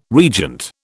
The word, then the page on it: regent: British English